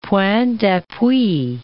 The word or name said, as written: point d'appui